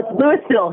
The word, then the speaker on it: Louisville: Louisville here in Colorado. – Oh. – You pronounce the S.